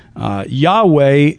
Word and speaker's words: Yahweh: And it's probably where we get the word Yahweh or Yahweh. It's the Y-H-W-H was probably a shortening of this whole phrase, I am who I am. Yahweh is how we believe it was pronounced, Yahweh or Yahweh.